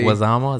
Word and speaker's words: Leguizamo: Leguizamo? Is that how you say it? – Yeah, man.